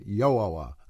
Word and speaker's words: Yahweh: Bishop Clement of Alexandria wrote in the 3rd century AD that the name was pronounced Yahweh. I don't know if I'm even saying that right. Yahweh, I should say. Y-A-O-W-E-H.